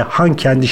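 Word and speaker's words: Hankendi: Karabağ'daki yerleşimlerin isimlerini Birleşmiş Milletler tarafından da kabul edildiği şekliyle Şuşa ve Han kendi şeklinde telaffuz edin dedi Bayramov.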